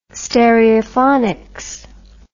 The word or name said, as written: stereophonics